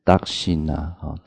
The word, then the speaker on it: Dakshina: 就是受施主供养以后的赞颂，那它的发音跟 Dana 很像， Dakshina， Dakshina，它跟 Dana 很像，所以也解说达称为布施的意思，但是意境呢，它是翻成清净，这大概给大家知道达称。